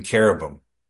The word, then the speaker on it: cherub: Next is cherub, C-H-E-R-U-B. Even though it's a C-H, it's pronounced with a K, cherub.